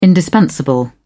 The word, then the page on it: indispensable: British English